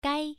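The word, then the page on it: gai: tone 1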